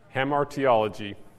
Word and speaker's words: hamartiology: Hamartiology? That's it. First time I've heard it, I think. Say it again. – Hamartiology.